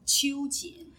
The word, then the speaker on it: Qiu Jin: Qiu Jin is the feminist.